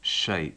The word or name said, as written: shape